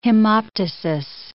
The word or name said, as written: hemoptysis